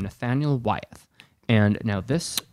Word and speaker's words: Wyeth: Wyeth, W-Y-T-H-E? – Yeah, did I mispronounce? – No, not at all. I was just wondering. I was wondering if it's like Wyeth, like, you know, all the things, like Wyeth Avenue. – W-Y-E-T-H is how I found this.